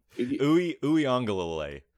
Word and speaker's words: Uiagalelei: So if you're a quarterback like DJ Ugalele, is that how you say it? – No. – Okay. – No. Uiagalelei.